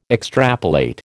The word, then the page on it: extrapolate: American English